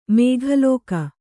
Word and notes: ಮೇಘಲೋಕ: mēgha lōka

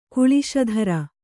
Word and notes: ಕಾಲಸೂತ್ರ: kāla sūtra

